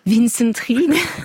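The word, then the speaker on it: Wintzenried: À la fin, quand il est évincé par le jeune Vincent Reed, qui devient l'amant de Madame de Vouarant. – Comment vous prononcez ça? – Vincent Reed. – Ah oui.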